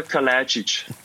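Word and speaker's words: Kalajdžić: Sascha Kalajdzic.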